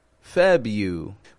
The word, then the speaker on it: February: I want to point out the R. It's a sneaky little R, the first R in February. The average American does not pronounce the R in February. Honest, we just don't. I'm sure there's some accents where they probably say February, February, but not where I'm from.